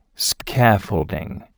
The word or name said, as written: scaffolding